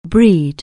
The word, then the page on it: breed: anglais